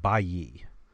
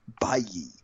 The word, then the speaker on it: Bailly: Um, Speaking of him, I believe, and I'll have to go back, but I believe it is pronounced Bayi. – Bayi. – Because of the double L.